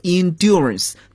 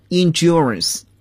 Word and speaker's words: endurance: 体育运动可以增强人的耐力，耐力 endurance 在英国英语中呢我们可以读作 endurance，但是在美国英语中呢我们发音就比较浊化一点了， endurance。